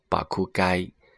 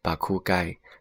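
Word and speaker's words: 爆買: 那爆买呢，读作巴库该，巴库该就写作爆买这两个字。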